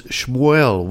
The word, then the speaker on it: Shmuel: My name is like, my Israeli name is Shmuel, which is like Samuel, but with an H instead of the S-A-M. – Yeah. – S-H-M.